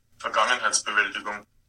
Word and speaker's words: Vergangenheitsbewältigung: Vergangenheitsbewältigung.